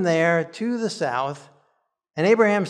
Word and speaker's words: Avraham: And if we're going to pronounce Avimelech as Avimelech, maybe we should have been pronouncing Abraham as Avraham. But we've been doing Abraham all along, so I think we'll keep with that for this week.